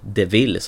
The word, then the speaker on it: de Vil: Och det passar ju bra med tanke på att Cruella Devil, eller Devil som det uttalas, som bilen.